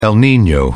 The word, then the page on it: el nino: British English